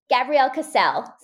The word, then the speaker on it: Gabrielle Kassel: Gabrielle Kassel. So it kind of rhymes.